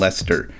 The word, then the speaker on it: Leicester: So, silent ice is very similar, like the city of Leicester in England. It is spelled L-E-I-C-E-S-T-E-R, and somehow they pronounce that Leicester, as if there's a silent ice in there.